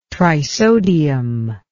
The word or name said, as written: trisodium